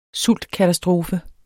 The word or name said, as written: sultkatastrofe